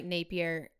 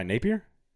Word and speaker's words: Napier: Someone like that, someone like Chadwell, someone like Napier. I always have a trouble with that. – Why, Napier? – Did I say it right? – No, yeah. You did.